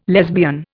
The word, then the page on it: lesbian: Indian